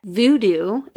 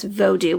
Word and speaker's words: vodou: And so Haitian, when I was watching this video, he pronounced it as vodou, which is V-O-D-O-U, instead of the voodoo that we're used to.